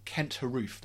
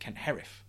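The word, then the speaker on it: Haruf: So there's an author called Kent Harouf. That's how it's spelt, but actually you pronounce it Kent Harif, apparently.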